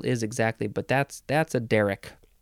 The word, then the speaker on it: derrick: Derrick, D-E-R-R-I-C-K, noun from circa 1752. And yes, Derrick, first syllable is emphasized.